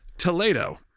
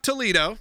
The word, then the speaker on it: Toledo: And you suggested, what I was corrected, is Toledo. Toledo, not Toledo. Mm. Though it's spelled the same. They will shame you. I told Will, I said, Will, this is going to make you real mad. Because you know this is like a Disney thing that's just going to make you mad. – No, it's actually Toledo. – It's Toledo. – Like in Spain? – Yeah.